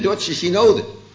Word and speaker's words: Dordtse synode: Onze vader, de Dordtse synode. Met de klemtoon op Dordt.